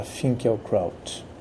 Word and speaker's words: Finkielkraut: Trouxe para conversar conosco o filósofo Alan Finkielkraut. Vamos aportuguesar um pouco a pronúncia e ficará Finkielkraut.